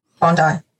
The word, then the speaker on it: Bondi: Am I saying that correctly or is it Bondi? Bondi. Bondi. So I was saying it correctly.